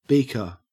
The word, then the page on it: beaker: Australian English